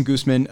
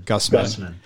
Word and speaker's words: Gusman: Jason, did I pronounce that right, by the way? – No. Okay. Sorry. – Okay. Well, we no pronounced it correct now. – Right. It's Guzman. – Guzman. Yeah. I tried to sex it up, and that's what I get.